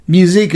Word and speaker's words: Musique: Musique is spelled like the word muse and then I-Q-U-E.